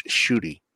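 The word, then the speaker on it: Ncuti: It's spelled N-C-U-I-T-I, but it's pronounced Shooty. – Yeah, Shooty, yes. I in my brain, I couldn't remember if it was C-H-O-O or S-H-O-O on the pronunciation.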